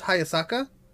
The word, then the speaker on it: Hayasaka: Oh, I Her it's still Hayasaka, like it's still pronounced Hayasaka, but it's spelled differently.